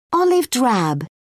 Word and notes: olive drab: British English

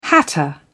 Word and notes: hatter: British English

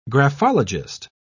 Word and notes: graphologist: British English

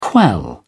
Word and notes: quell: British English

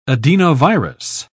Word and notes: adenovirus: British English

